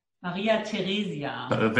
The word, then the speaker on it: Maria Theresia: When you say her name, how do you pronounce her name? – Maria Theresia.